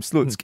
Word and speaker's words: Slutsk: You spell it S-L-U-T-S. K. So it's Slutsk. – It's Slutsk. And don't get confused with the word sluts with a K on the end, because that's not what it is.